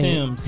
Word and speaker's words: Tems: Is it, how do you say Tim's. – It? Tim's. – T-E-M-S.